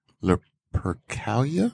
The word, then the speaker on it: Lupercalia: Has its origins in the Roman festival of Lupercalia.